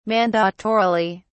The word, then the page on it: mandatorily: British English